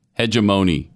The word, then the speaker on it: hegemony: Hegemony. Yeah. Yep. Instead of ma, it's moan.